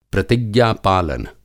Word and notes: प्रतिज्ञा-पालन: Hindi

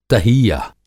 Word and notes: तहीय:: تہيہ

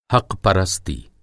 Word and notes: हक़-परस्ती: حق پرستی

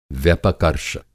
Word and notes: व्यपर्कष: Hindi

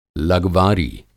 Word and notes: लगवारी: Hindi

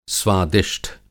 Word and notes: स्वादिष्ठ: Hindi

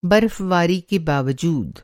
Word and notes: बर्फ़बारी: برف باری

